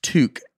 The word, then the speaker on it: toque: I am Canadian and it's pronounced toque as in fluke. Toque, fluke.